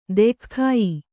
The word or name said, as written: ದೆಖ್ಖಾಯ